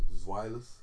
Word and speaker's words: Zweilous: Zweilous. That's how you say it.